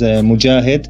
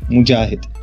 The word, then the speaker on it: Mogahed: Hi, for you and for your audience, you can pronounce my name as Mujahid, M-O-G-A-H-E-D, Mujahid.